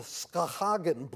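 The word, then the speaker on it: Schaghen: It is more or less the Schahagen brief,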